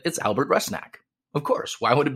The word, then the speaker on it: Rusnak: So that you can all be better prepared for my goal-scoring announcements this season, the pronunciation of our new designated player's name is Rusnak, Albert Rusnak.